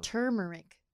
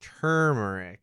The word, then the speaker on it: turmeric: And some of these will just earn you cubes of various colors of cinnamon and saffron and turmeric. Turmeric. I always forget that there's a secret R in there no one ever pronounces. – Oh, no, I mispronounce it too. – Turmeric.